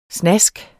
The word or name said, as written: snask